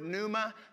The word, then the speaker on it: pneuma: In the Greek language, it is the word pneuma, spelt with a P, where we get our English words pneumatic, pneumatic tires, pneumatic drills, pneumatic tools.